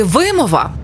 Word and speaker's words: вимова: Вимова чи вимова? Правильно, вимова.